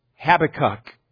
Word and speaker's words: Habakkuk: And first of all, with respect to his name, there's the question of how do we even pronounce this name? Is it Habakkuk or is it Habakkuk? Well, I believe the proper way, the better way to say it is Habakkuk.